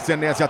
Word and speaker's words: Senesi: A gente vai de Marco Senesi ou Senesi, a pronúncia correta? – Senesi.